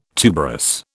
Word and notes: tuberous: British English

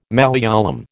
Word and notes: Malayalam: American English

